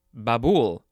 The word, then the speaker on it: Babul: Okay, now we have Babul, B-A-B-U-L. The emphasis is on the second syllable.